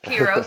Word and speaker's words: Ki-Ro: And at first I was like, is that Kika's and Roberto's name tied together for Kiro? Is that how you pronounce it? – So, yes, Kiro.